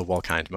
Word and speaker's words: Wolkind: It's actually a long I, so Wolkind most of the time. But people frequently get it wrong, so I respond to both.